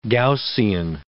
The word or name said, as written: Gaussian